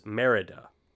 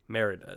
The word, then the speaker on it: Merida: It is, Merida. Well, you pronounce it like three different ways. – Merida. – It's Merida. Merida is how you pronounce it.